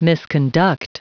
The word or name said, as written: misconduct